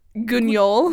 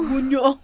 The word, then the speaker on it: Guignol: Guignol? – Guignol.